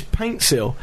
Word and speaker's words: Paintsil: Well, I keep getting his name wrong. Yeah. Pansil's not even his name. His name is Paintsil, but it's always been spelt wrong by the Ghanaian FA, apparently.